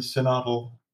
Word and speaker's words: synodal: So have you all participated in the synodal processes in the diocese? – No, yeah. – Is that how you pronounce it? – Yes. – Yes.